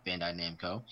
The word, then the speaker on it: Bandai Namco: The reason I said all that is because during our pre-show discussion, I butchered the name and said everything else but Bandai Namco. But now that I've got it, Bandai Namco.